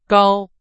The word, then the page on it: 高: Chinese